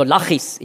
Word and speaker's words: Lachis: Also Lachis, ich habe das vorhin falsch gesagt, Lais.